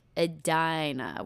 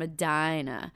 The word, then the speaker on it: Edina: which are those people from Edina. Now, why are you saying it like that? – Because that's how you say it. – Edina? I'm from here. You say Edina.